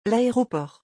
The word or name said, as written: l'aéroport